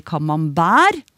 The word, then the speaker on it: camembert: Og vi uttaler ikke t-en i camembert. Pleier i hvert fall ikke å si camembert.